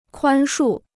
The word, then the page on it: 宽恕: kuān shù